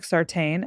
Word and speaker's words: Sartain: Please make sure to follow me on Instagram, both at Peyton Sartain. It looks like Sartain, S-A-R-T-A-I-N. And my first name is spelled with an A, P-A-Y-T-O-N.